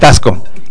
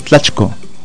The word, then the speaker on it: tlachco: O sea, ya en español podemos decir tasco, pero en el náhuatl es tlachco.